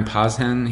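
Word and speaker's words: Posehn: I'm not saying his name right. I'm sorry. Brian Poshen.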